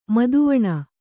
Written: ಮುದಿವೆಣ್

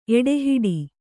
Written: ಎಡೆಹೊಡೆ

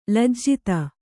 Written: ಲಜ್ಜಿತ

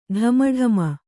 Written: ಢಮಢಮ

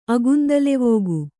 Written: ಅಗುಂದಲೆವೋಗು

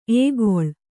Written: ಎಕ್ಕಲ

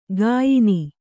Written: ಗಾಯನಿ

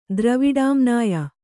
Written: ದ್ರವಿಡಾಮ್ನಾಯ

